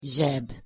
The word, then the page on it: zseb: Hungarian